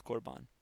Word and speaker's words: korban: Sounds like carb. It looks like carbon, but it's pronounced korban.